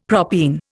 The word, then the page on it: propene: Indian